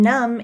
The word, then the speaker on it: numb: And numb is pronounced without the B sound.